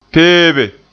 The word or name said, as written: peebe